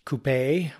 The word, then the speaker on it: coupé: Yeah, and the Wikipedia article is for the word coupé, which is the French or European pronunciation of what here in North America we call coupe.